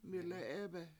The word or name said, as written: mI7le7 7Ebe7